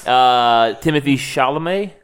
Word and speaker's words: Timothée Chalamet: Timothée Chalamet. – Yeah. Yep. – Is going to star. – Oh, sorry. You were waiting for us to correct you. You said it correctly. – That was right.